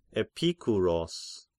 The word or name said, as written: Epikuros